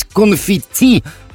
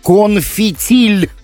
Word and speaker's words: конфетти: В 27 лет узнал, что правильно говорить «конфетти», а не «конфетиль».